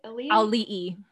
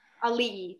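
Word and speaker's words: Auli'i: I don't remember what award show it was, but how do you say her name? – Ali'i. – Ali'i, thank you.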